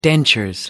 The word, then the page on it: dentures: British English